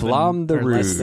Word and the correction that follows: Flamme Rouge: Flamme Rouge.